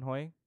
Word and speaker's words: Hoi: And then Jinju's like, so, do you know a guy named, how do you pronounce his name again? I forgot to make a note of that. Is it Chamberlain Hoi? – Yeah, Hoi, that's right.